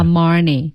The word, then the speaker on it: Armani: Yeah G-I-O G-I-O G-I-O-R-G-I-O 对，它是 G-I-O-R-G-I-O. – Yeah, Giorgio. – Giorgio. – Yeah. Armani. A-R-M-A-N-I. Yeah. – Armani. – Armani. – Yeah. Armani.